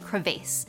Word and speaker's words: crevasse: Crayfish comes from the Middle English crevice, derived from the Anglo-French crevasse, emphasis on the vase.